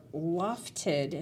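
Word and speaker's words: LOFTID: That's slated to launch Wednesday, the low Earth orbit flight test of an inflatable decelerator, or lofted, and please, I hope I'm saying that right. – Yes.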